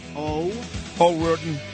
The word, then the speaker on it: O'Riordan: Once again, a very happy birthday here to my friend Dolores Riordan, the lead singer here of the Cranberries. – Oh, how do you say it? – O'Riordan. O'Riordan.